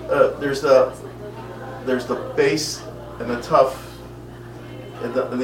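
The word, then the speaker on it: Tuf (תו): And the Aleph Bet, the last reverse of it is, what do you say, Tuf and Shin, right? Is that how you say it? – Are you talking about the last two letters? – Yes. – Yeah, are Tuf and then Shin. Yeah.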